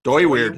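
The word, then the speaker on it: Dooyeweerd: I looked into, I don't know how to pronounce this guy's name. Doeweerd? – Doeweerd. – Doeweerd. – Doeweerd. Okay. Doeweerd. – Yes.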